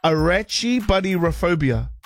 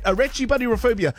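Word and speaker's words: Arachibutyrophobia: I'm going to say that again because I probably butchered it. Arachibuddyrophobia. Yeah, that's right. Arachibuddyrophobia.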